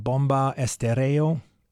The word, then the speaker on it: Bomba Estéreo: They're called Bomba Estéreo, actually. The stress is on the second syllable.